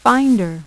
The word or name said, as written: Finder